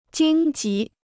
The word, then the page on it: 荆棘: jīng jí